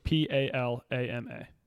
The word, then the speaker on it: palama: P. A. L. A. M. A.